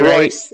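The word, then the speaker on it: Reus: Obviously, he had Marco Ruiz doing what Marco Ruiz – I think I pronounced that right, Royce. It's Marco Royce.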